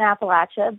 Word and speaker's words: Appalachia: Yeah, so I guess it depends on where you're from in Appalachia, but when I first moved down here, I was corrected quite often that it's Appalachia.